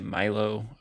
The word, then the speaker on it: Milio: A lot of people say Milo, M-I-L-I-O. I've never really learned how it's pronounced definitively. I think you can pronounce it either way you want. I say Milio.